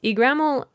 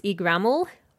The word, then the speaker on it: Yggdrasil: by his despair upon hearing this news, Yggdrasil tells him her secret,